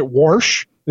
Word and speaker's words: wash: For instance, when people take the word wash and pronounce it warsh, they throw an R in there.